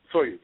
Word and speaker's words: Soyuz: Soyuz, yes.